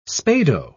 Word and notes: spado: British English

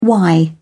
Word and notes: y: British English

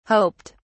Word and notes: hoped: British English

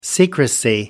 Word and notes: secrecy: American English

